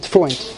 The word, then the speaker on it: Freund: Tuvia Pei Resh Yud Yud Nun Dalet. What? Freed. No. Freund. I have Tuvia Freund.